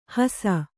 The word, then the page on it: ಹಸ: hasa